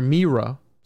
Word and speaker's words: Myra: But it is said that in Myra. Or Mira, sorry, I've been saying that wrong.